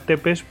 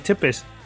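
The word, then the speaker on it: Țepeș: Vlad Tepes para, para la mayoría de los hispanohablantes, pero bueno, Se dice Chepes. Sí, por lo visto me comentaron a mí algunos colegas rumanos que se pronunciaba Chepes, me corrigieron ahí en plan de tomándoselo mal.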